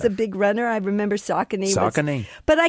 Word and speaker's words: Saucony: So did I pronounce Saucony right? – You got Saucony right, which is incredible.